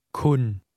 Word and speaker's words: คุณ: คุณเข้าใจครับคุณ The Thai word you is pronounced with a mid-level tone of voice, neither high nor low.